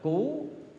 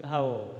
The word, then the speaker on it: hầu: Cái chữ hầu thanh đấy, tị bên chữ cú, một số đọc là cú, nhưng mà nó là chữ hầu.